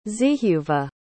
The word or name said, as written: zehuva